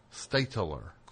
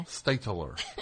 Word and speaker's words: Stateler: Stitler. – Stitler. – Stitler. Two syllables. – Stateler. – Ay. – Is that it? – Yeah. – Stateler.